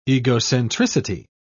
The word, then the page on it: egocentricity: British English